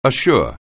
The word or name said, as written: assure